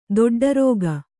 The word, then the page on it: ದೊಡ್ಡರಾಗಿ: doḍḍa rāgi